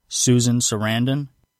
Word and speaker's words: Susan Sarandon: Susan Sarandon.